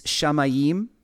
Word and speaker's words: shamayim: The word is pronounced shama. If it's plural, if you're talking about heavens, it's shamayim.